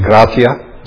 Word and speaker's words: gratia: I think the correct pronunciation is gratia, G-R-A-T-I-A.